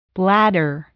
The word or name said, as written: bladder